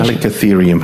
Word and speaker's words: Chalicotherium: Well, one of the creatures that was around at that time, Chalicotheres. How's my pronunciation? – Chalicotherium. – Chalicotherium.